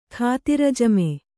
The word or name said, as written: ಖಾತಿರಜಮೆ